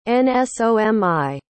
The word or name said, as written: nsomi